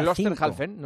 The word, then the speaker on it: Klosterhalfen: Klosterhalfen. – Constanz Klosterhalfen. – Que hizo 1:05. – Klosterhalfen, no es tan difícil. Hay que dividirlo en dos. Klosterhalfen, ya está.